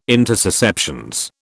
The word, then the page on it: intussusceptions: British English